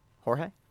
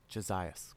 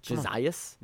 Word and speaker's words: Josias: Say his first name. – Jorge? – No. – I can't. – Jay-Z. – I can't do Jay-Z's first name. – Josias. – Josias? – Yeah. – Yeah, man, it's spelled funny.